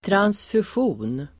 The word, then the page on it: transfusion: [transfusj'o:n]